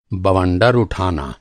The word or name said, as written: बवंडर